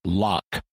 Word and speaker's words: Loch: And since I'm a language nerd, I will be seeking to pronounce the ch on the end of Loch. In standard English accents, this gets pronounced with a K sound, you know, Loch. But we get so few chances to use guttural consonants like ch in English, and Scottish pronunciation happens to have one.